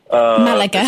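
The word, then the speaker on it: Málaga: And I know that I don't know if I pronounce it right, Malaga. – Malaga.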